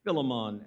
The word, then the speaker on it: Philemon: Philippians and Philemon, or Philemon as some pronounce it.